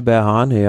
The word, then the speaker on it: Berhane: Habe ich das richtig ausgesprochen? Berhane? – Natnahel Berhane, ja,